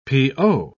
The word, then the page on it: p.o.: British English